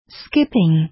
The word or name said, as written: skipping